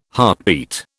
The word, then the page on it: heartbeat: British English